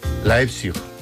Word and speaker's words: Leipzig: Leipzig. ¿Lo he dicho bien? – Qué importante saber idiomas. – Tengo aquí a mi amigo que me lo dice. ¿Cómo se dice? – Leipzig. – Leipzig. Leipzig, ¿ves? – Leipzig, muy bien. – Venga, muy bien. – Es que claro, es alemana.